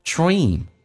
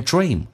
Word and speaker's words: dream: Dream, 长元音，很多人发成 dream 是发成 a 这个发音，事实上是 e dream，嘴角往后拉，不要往上打开。